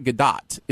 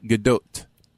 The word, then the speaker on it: Gadot: The T is pronounced, but it's Gadot. – Yeah. – So it's not Gadot, you would think a silent T, and it's Gadot. Also not Gadot. It's like Gadot. It's like it's got a little oot.